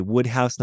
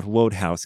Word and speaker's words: Wodehouse: So I only this week found out you say Wodehouse, not Wodehouse, because it's spelled W-O-D-E, and I wanted to do the magic E rule and make that an O sound, but apparently that's wrong.